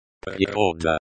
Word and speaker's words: Природа: Природа. Природа. Nature. Noun. Feminine. This is the main noun in the sentence. The stress is on the second syllable, ro.